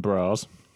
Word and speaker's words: Bras: Marie Sabare as Mado Le Grand Bras. – Mm. – That S isn't pronounced, but it says Bras, so I'm saying Bras.